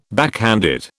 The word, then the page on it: backhanded: British English